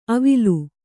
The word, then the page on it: ಅವಿಲು: avilu